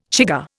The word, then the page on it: chigger: Indian